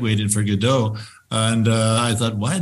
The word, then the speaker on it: Godot: Well, I first have to say, Norm, I say Waiting for Godot. Okay, I think I'm gonna wager a guess that most of my audience is going to be surprised that the story that is pronounced Godot.